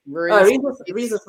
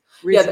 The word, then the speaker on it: rhesus: These are rhesus. I don't know how to pronounce it. – Rhesus monkeys. – Rhesus. Yeah, okay.